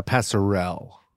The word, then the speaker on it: passerelle: it's actually pronounced uh passerelle passerelle, sorry.